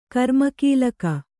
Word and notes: ಕರ್ಮಕೀಲಕ: Kannada

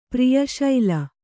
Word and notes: ಪ್ರಿಯಶೈಲ: priya śaila

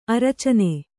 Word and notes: ಅರಚಣ್ಣ: aracaṇṇa

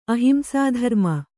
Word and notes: ಅಹಿಂಸಾಧರ್ಮ: ahimsādharma

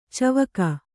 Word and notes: ಚಿವಿಕೆ: Kannada

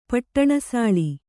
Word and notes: ಪಟ್ಟಣಸಾಳಿ: kannada

